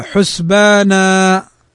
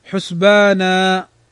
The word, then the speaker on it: حسبانا: لو وقفنا على كلمة حسبانا فإننا ننطقها هكذا: حسبانا، لا ننطق الفتحتين، إنما نقول حسبانا، فهذا يسمى مد العوض، ويمد بمقدار حركتين كما ذكر أهل العلم.